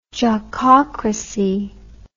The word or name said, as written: jockocracy